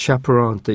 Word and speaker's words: chaperone: シャプロンと言いますね、お目付け役のような意味だと思っていいですね。